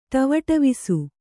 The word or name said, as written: ಟವಟವಿಸು